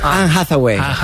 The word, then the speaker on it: Anne Hathaway: Anne Hathaway. – Eva, ¿está bien dicho? Eva, está perfectamente dicho.